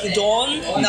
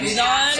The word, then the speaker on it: udon: It's pronounced udon, not udon.